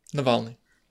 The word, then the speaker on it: Navalny: Did I say his name right? Is it Navalny? Navalny. – Navalny. – Navalny. I knew how to say it. Navalny, yeah.